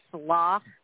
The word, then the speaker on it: slough: We live right by something called a slough. – Okay. – Which is it's pronounced how it smells.